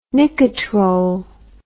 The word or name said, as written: Nicotrol